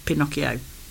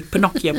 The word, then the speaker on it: Pinocchio: For 2 weeks, and they're going to create the real story of Pinocchio. So we leave Disney far behind. – Excellent. And you pronounced it the proper way as well. – Well, it's Italian, so it has to be Pinocchio.